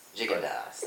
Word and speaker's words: Gigondas: Gigondas, that's it. – So. – You don't have to say it like you're French.